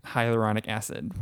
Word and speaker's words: hyaluronic acid: She taught us all how to say it. Hyaluronic acid. – Yep, that's it.